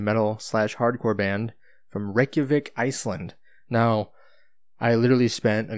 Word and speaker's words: Reykjavik: it's spelled R E Y K J A V I K, but it's pronounced Reykjavik.